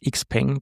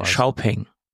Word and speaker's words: Xiaopeng: Nur eine kleine Klugscheißer-Einlage, es heißt nicht Xpeng. Das wird zwar akzeptiert, aber es wird nicht so ausgesprochen. Es heißt Xiaopeng.